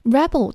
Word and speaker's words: rebel: Rebel 做名词的时候表示叛军，而且这个词做动词的时候发音是不一样的，读作 rebel 表示反抗。